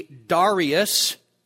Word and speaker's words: Darius: It actually should be Darius, but if I say it differently, I'm sorry. But there is a big question, and that is, who was he? Some commentators say we just don't know who Darius was,